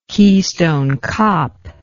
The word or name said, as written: Keystone Kop